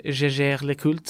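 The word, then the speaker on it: Jaeger-LeCoultre: Og så har du jo nå da selvfølgelig med de mer klassiske klokkene, som er Jeger Le Cult, eller Jeger Le Cult, som det egentlig uttales.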